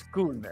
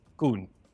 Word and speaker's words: Kun: It's Kun, actually. – Kun. Oh, okay. Kun. Say it again. – Kun. A proper U sound. Kun.